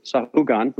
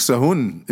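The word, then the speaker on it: Sahagún: But she did manage to tell me that the town I was going to, Sahugan, am I saying that right? – Yeah, I think Sahun is how you, I've always called Sahun, it Sahun. Sahun.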